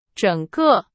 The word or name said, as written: 整个